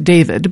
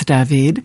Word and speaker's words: David: It's, in English, would be pronounced David, but in French it's David.